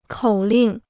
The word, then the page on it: 口令: kǒu lìng